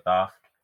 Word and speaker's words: Thoth: After a disappointing barbecue with Throth? – That's an email we got that I pronounce it Thoth and you pronounce it Throth, and they're like, where the f*** is the R? – Good point.